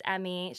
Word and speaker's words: Emme: My name is Emmy, short for Emerald. It's spelled E-M-M-E.